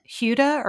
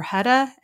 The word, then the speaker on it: Huda: I don't know if I'm going to say the name right, but it's it's Huda or Huda H U D A.